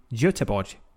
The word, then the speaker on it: Göteborg: On Saturday, the 21st of May, there will be a Skeptics in the Pub social event in Göteborg. Did I pronounce it well, Pontus? – Perfectly.